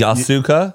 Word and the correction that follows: Yakuza: Isn't it the Yasuka? Am I saying that right? – Yakuza. Yakuza.